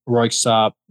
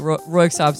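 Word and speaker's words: Röyksopp: But I love a place like, obviously, some like Groove Armada and some like Fatboy Slim tracks, Daft Punk, Royksopp. I don't even know if I pronounced it right. rock. – That's how I say it. – Yeah. – Yeah. – Well, a classic band that I've almost only ever seen written. – Yeah. So I'm sure it has some obscure, like, Scandinavian actual pronunciation, but Royksopp sounds about right to me.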